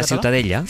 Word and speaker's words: Ciutadella: Para mí la Ciutadella es... ¿cómo se pronuncia? – La Ciutadella.